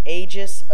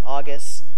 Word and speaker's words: Aegis: The other shield is uh, called the, and I pronounce it Aegis of Stormwind. Some people pronounce it pronounce it August.